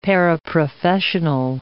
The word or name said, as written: paraprofessional